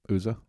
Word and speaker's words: Uzzah: And later we'll see it with, is it Uzzah? Is that how you say his name? – Yeah, Uzzah.